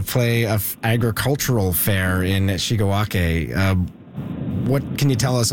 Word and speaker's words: Shigawake: Like Shigawake, which is how the locals pronounce it around there, has had an agricultural festival like you would imagine agricultural festivals, with a horse pull and baking competitions for around 110 years.